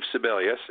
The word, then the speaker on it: Sebelius: Uh, that's at Steve Sebelius, and it is spelled. That last name is spelled S E B E L I U S.